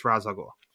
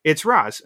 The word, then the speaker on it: Ra's al Ghul: No, we know from the beginning it's Ra's al Ghul or Ra's al Ghul, however you prefer to pronounce it. It's Ra's.